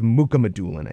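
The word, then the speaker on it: Mukhamadullin: If they can lock up Meyer for the long term, and it's Makamadulin, I think. Is how I'm getting word on that pronunciation.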